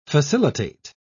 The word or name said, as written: facilitate